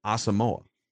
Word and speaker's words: Asamoah: So they put pronunciations in here. – Correct. And Pro Football Reference also has it as Asamoah. But people, there's like a wave of people this week that are saying it's Asamoah. Brian Asamoah. Until the Vikings say otherwise, we're gonna go with their pronunciation, Asamoah.